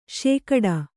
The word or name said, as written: ಶೇಕಡಾ